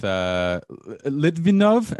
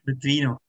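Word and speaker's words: Litvínov: So right around when it was, that was what, the under 16 team with Litvinov? Am I saying that right? – Litvinov. – not even close.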